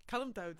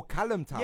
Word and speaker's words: Kalmthout: Like, last night, someone pointed out that I was saying the name wrong of the place. I was just saying, somewhere near Antwerp. – Yeah. – But Kalmthout? – Kalmthout. – Oh, Kalmthout.